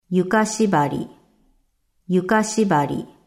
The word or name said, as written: Yuka shibari